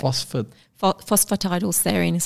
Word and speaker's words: phosphatidylserine: And phosphatidylserine. – The one that I can't pronounce. Yeah. – Phosphatidylserine. So it's spelled. – Phosphatidylserine. – Yeah. P-H-O-S-P-H-A-T-I-D-Y-L.